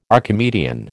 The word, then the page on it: Archimedean: American English